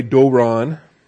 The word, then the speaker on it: doron: Doron. D-O-W-O-N. D-O-R-O-N. The long O is the omega, the short O is the omicron. You have doron.